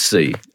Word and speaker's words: Sy: In fact, I think it's pronounced C. – Is it C? – Funnily enough, C, it's Omar Sy.